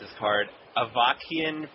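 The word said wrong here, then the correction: Avacynian: Priest? – Avicennian Priest. – Oh, Avicennian, yeah.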